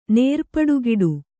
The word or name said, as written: ನೇರ್ಪಡುಗಿಡು